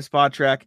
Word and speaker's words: Spotrac: Keith Smith, Celtics blog, and SpotTrack. I was about to check how to pronounce SpotTrack again because I thought I'd get it wrong, but I think I had it, so I didn't bother.